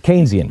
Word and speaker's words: Keynesian: Keynesian.